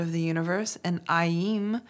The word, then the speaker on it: Aim: Aim is a Bija mantra. It's spelled A-I-M and pronounced Aim.